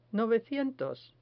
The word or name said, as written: novecientos